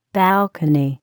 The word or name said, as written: balcony